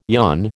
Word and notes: yon: American English

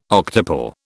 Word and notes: octuple: British English

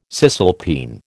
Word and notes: cisalpine: American English

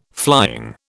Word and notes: flying: British English

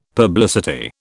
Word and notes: publicity: British English

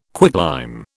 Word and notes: quicklime: British English

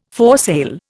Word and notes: foresail: Indian

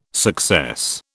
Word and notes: success: British English

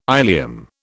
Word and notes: ilium: British English

